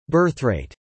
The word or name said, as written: birthrate